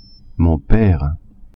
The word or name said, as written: pere